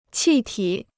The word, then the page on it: 气体: qì tǐ